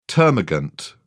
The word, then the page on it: termagant: British English